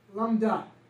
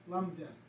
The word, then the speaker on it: lambda: So, S-L-M-B, right? So, S is Sigma. That's pretty close. Sigma. L. Lambda. Lambda, L-A-M-B-D-A is how you pronounce it.